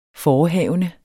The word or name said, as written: forehavende